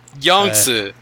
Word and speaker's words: Yangtze: Yangtze.